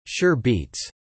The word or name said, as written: Sure Beats